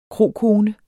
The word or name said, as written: krokone